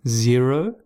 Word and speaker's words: Xero: Client invoices and accounting, Xero, but it is spelt with an X at the front, X-E-R-O.